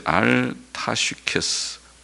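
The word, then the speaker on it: 알다스헷: 영장을 위해 알다셋, 알타슈케스 원어의 발음대로 하면 알타슈케스라는 말은 멸하지 말라라는 곡 이름이라고 봅니다.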